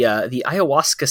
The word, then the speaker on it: ayahuasca: Well, another one of the experiences that you wrote to me about was the ayahuasca ceremony in Peru. – Yes. Yes. – Am I saying that right? I think. – Yes. – Is that how you? – Yeah.